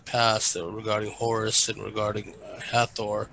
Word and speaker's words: Hathor: The Hathor aspect, Hathor, and I'll go by your pronunciation.